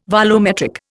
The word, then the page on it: volumetric: Indian